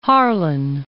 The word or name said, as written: Harlan